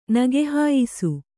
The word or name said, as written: ನಗೆಹಾಯಿಸು